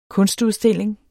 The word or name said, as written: kunstudstilling